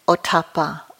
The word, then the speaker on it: Ottappa: In the ancient language of Pali that the Buddha's teachings were translated from, these are known as Hiri, and it's spelled H-I-R-I, and Ottappa, O-T-T-A-P-P-A, for those of you who are interested, but you don't have to remember that.